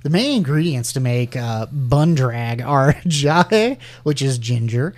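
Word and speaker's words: bandrek: This one, I would pronounce this Bondrek, but it's actually pronounced Bundrag.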